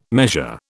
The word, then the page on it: measure: British English